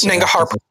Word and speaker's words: Nangarhar: Nangarhar.